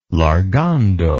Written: largando

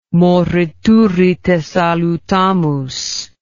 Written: morituri te salutamus

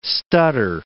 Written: stutter

